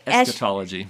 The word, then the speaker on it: eschatology: Did I say it right? – Eschatology.